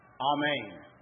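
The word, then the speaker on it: amen (ἀμήν): In fact, it's pronounced like this, amen. Amen, amen. As we pray, we say, let it be so when we close our prayers.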